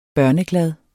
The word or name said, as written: børneglad